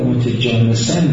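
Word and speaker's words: متجانسین: اعتبار متجانسن بگیم، ما داریم اشتباه تلفظ میکنیم، متجانس دیگه ولی و متناجس بله چقدر با دقت هم تلفظ میکردیم، هرچی بیشتر دقت کردیم برعکس تلفظ، متجانسن درست شد بالاخره؟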